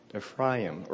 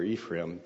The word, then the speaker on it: Ephraim: The Samaritans were descendants of Joseph through his son Ephraim, or Ephraim, depending on if you're Jewish or if you're a Western American, how you pronounce it.